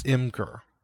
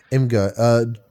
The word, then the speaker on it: Imgur: is it pronounced Imgur or Imgur? – I think it's Imgur. – Imgur.